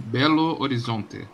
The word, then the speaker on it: Belo Horizonte: I am from Belo Horizonte.